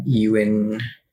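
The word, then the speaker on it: Ewen: Like, anyway, so now I would like to introduce you to our enemy, Dr. Donald Ewan Cameron. I think it's pronounced Ewan, whatever. – Ewan.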